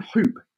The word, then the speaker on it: Huupe: And the name Huupe is spelt H-U-U-P-E. So again, a bit more sort of the edgy style. So not Huupe, H-O-O-P, it's H-U-U-P-E. There you go. We're talking about Huupe.